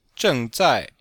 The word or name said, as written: zheng4zai4